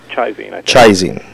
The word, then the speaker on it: ChiZine: The World More Full of Weeping is published by Chizine Publications. Did I say that right, Rob? – Chizine, pardon me.